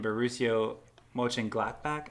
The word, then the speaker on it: Mönchengladbach: No, they got Bayer Leverkusen and Borussia Mönchengladbach. I think is how you say it. I'm the worst. – That sounds good to me. – Yeah, nailed it first try.